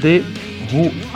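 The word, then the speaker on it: The Hu: Se pronuncia The Who, H-U.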